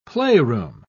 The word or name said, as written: playroom